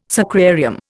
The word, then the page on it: sacrarium: Indian